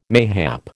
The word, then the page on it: mayhap: American English